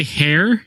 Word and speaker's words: Heir: That's actually how they say it in the the audio version of the book. – Is it really? – Yeah. – To the Jedi. – They say hair.